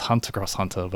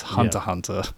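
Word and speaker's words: Hunter × Hunter: There's another manga called Hunter Hunter that I've always pronounced as Hunter Cross Hunter, but apparently it's Hunter Hunter.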